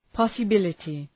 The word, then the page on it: possibility: ,pɒsə’bılətı